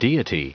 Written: deity

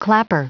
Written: clapper

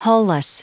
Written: hull-less

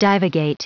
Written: divagate

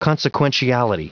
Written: consequentiality

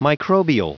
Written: microbial

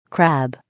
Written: crab

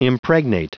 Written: impregnate

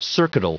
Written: circuital

